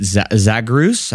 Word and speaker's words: Zagreus: I really like, is it Zagreus? I'm not even sure how to say this word. – Yep.